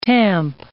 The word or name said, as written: tamp